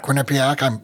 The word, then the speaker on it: Quinnipiac: The other was from, and I'm going to see if I can pronounce this correctly, Quinnipiac. Is that right? – Quinnipiac.